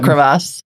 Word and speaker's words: crevasse: Well, I'm glad you didn't fall into a crevice when you had your carafe. – A crevasse.